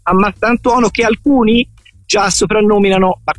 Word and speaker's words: Mastantuono: Mattantonio, ho sentito qualcuno. – Sì, la pronuncia, diciamo, che è stata storpiata da più parti e da più persone, sì, ma insomma l'importante è che poi Fulmini, che si ricorderebbe, sì, Toni, sì.